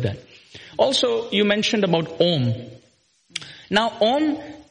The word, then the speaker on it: Om: In the seventh chapter of the Gita, he says, among all sound vibrations, I am Om. So this word om also comes with three syllables. A, U and Ma.